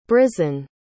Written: brysen

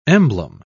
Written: emblem

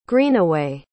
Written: greenaway

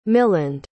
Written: miland

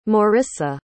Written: morrisa

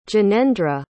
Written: jinendra

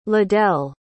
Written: ledell

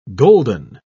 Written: golden

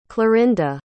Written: clarinda

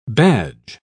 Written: badge

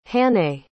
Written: hannay

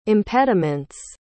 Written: impediments

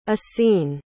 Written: essene

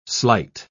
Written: sleight

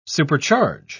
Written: supercharge

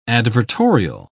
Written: advertorial

